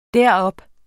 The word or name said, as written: derop